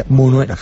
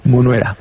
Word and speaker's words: Munuera: Martínez Minera. – Munuera, perdón que se me... – Da igual. – Munuera.